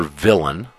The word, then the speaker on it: villein: If you go back to Black's Law Dictionary and look under villain, it's spelled French, V-I-L-L-E-I-N, and pronounced, if it was pronounced like it's spelled, it would be called a villein. But we English-oriented folks call it a villain.